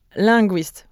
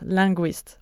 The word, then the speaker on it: linguiste: Yeah, je suis linguiste. We just pronounce linguiste.